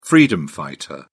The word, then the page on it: freedom fighter: British English